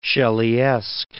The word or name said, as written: Shelleyesque